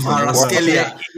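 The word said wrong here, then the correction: Kvaratskhelia: It's pronounced Kwaraskelia.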